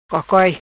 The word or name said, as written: Cocoi